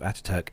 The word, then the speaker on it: Atatürk: So the gradual shift from Atatürk Airport, I think that's how you pronounce it? Atatürk? – Yes, yeah. – Atatürk, yeah.